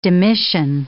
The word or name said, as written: demission